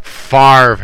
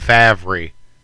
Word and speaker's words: Favre: It's kind of like Favre and Favre. But it's spelled F A V R E, Favre.